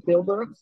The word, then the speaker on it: Tilburg: is it Breda and is it Tilburg? – That's not bad. Breda and Tilburg, it's not bad.